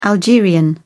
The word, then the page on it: algerian: British English